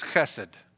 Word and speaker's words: chesed: but the Hebrew word is chesed. You know, you got to have a good amount of phlegm in the back of your throat in order to pronounce it.